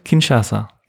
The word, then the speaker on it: Kinshasa: März 87 in Kinshasa.